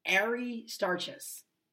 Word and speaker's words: Aristarchus: But, you know, the second faithful friend that Paul mentions is Aristarchus. I'm probably saying it right. Aristarchus. A-R-I-S-T-A-R, like Aries, like Ari. Aristarchus in verse 10.